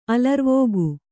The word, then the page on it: ಅಲರ್ವಕ್ಕಿ: alarvakki